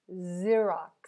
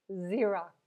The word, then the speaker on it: Xerox: And one more time, Xerox. Well done. This is tricky because you have two X's here. So the first one is pronounced like Z, and the last one is pronounced like an X.